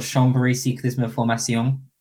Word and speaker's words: Chambéry Cyclisme Formation: So we're technically called Chambéry Cyclisme Formation, to give it the proper French pronunciation.